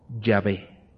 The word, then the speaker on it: Yahvé: Los estudiosos de la Biblia han propuesto que la verdadera pronunciación del nombre de Dios es Yahvé. Yahvé. Entonces, la correcta pronunciación según estos eruditos es Yahvé, el yo soy.